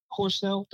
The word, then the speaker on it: Horsnell: Horsnell.